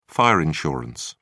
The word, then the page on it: fire insurance: British English